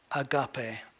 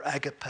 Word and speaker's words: agape: But of course, the greatest word is agape or agape, whoever you want to pronounce that.